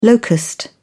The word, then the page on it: locust: British English